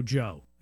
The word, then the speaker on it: Joe: People ask me, why do you call yourself Checka Pro Joe? And it's very simple, but Joe rhymes with pro, and I thought it would be pretty easy to remember.